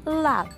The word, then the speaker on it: larb: I'll take the maggot larb. Did I say that right? Larb? – It's actually pronounced lab.